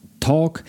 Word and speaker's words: talk: Pamiętajcie, że w słowie talk pisze się literkę ly, ale tej literki ly się nie wymawia.